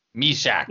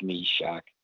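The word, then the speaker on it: Myšák: I had a chat with Trevor about, you know, how you pronounce it, and I think it's Mishak. You know, I said, well, I wouldn't want to be called my sack, you know? – No!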